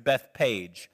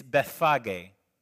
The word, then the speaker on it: Bethphage: I've always heard Bethpage. It's Bethphage.